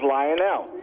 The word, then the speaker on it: Lionel: My name is Lionel. – Lionel, like the train. – Exactly.